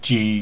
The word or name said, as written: ji